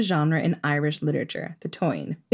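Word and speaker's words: Táin: We did an episode about the cattle raid of Cooley, and throughout it, we called it the tain, which is not the correct pronunciation as far as I can tell.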